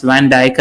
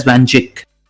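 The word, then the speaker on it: Van Dijk: I think I've not had a drink on the pod for only one episode, and that was the episode I pronounced Van Dyke as Van Jick.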